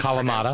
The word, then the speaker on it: Kalamata: and Kalamata olives. Is that how you would Kalamata.